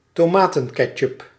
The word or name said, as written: ketchup